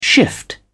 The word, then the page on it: shift: British English